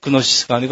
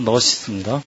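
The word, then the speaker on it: 너시스입니다 (γνῶσις): 여기서 지는 묵음이니까 그노시스가 아니고 너시스입니다.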